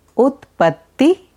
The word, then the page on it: Utpatti: Sanskrit